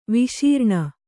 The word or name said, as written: ವಿಶರಣ